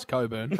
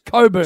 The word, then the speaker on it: Cockburn: No, it isn't. It's Coburn. – How do you spell it? – I lived in the joint for 20 years. It's called Coburn. – How do you spell it? – I don't care how you spell it. It's pronounced Coburn. – How do you spell it? That's a question. – Bill, no. – C-O-C-K.